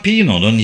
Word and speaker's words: Pino: Ama sonra Pino'nun yazıldığı gibi okunuyor, 60 yıl sonra hala hayatta olduğunu Kaliforniya'da Beverly Hills ve Mammoth Lakes'te yaklaşık 30 sene yaşadıktan sonra yeniden İtalya'ya döndüğünü öğrendim.